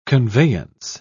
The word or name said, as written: conveyance